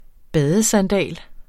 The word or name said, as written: badesandal